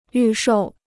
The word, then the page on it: 预售: yù shòu